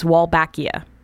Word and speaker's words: Wolbachia: And the, the, the bacteria I'm talking about, and I'm gonna totally butcher the pronunciation, I'm sure, is Wolbachia.